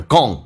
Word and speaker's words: gone: 这个发音在美式发音叫 gone, 而且是前鼻音，不要发成 gone, no, 也要发成 gone, alright, gone, g 加个 n, 前鼻音 gone, Fortune will be gone.